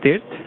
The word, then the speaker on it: Tirth: My name is Tirth.